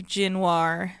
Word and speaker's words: Jinwar: So I'm talking about the village of Jinwar, and that's spelled J-I-N-W-A-R.